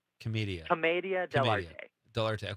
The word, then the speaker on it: Commedia dell'arte: Commedia dell'arte.